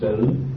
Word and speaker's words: xử: Chư Phật tùng bổn lai, thường xử chữ chữ xứ mà đọc là xử, thường xử ư tam độc.